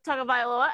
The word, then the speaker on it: Tagovailoa: But shout out to Tagovailoa, and I actually just said the name right, yay.